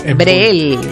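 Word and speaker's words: Breel: Bril. – Se escribe B larga R E E L. – Bril. – Brel.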